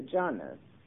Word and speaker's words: jhana: Whereas in the meditative process of the jhana, which is a Pali word for meditative absorptions, and because it's so much shorter, I would now like to use it, it only has two syllables.